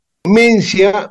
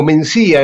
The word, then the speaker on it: Mencía: Entonces, Mencia o Mencía en realidad algunos le dicen Mencía y otros Mencia, pero yo le voy a decir Mencia porque son dos sílabas en vez de tres y estoy resfriado y me es más fácil, dos sílabas.